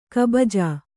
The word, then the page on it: ಕುಬುಜ: Kannada